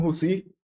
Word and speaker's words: Rucy: E para me seguir, Bruno Russi, R-U-C-Y, tem que pronunciar, né?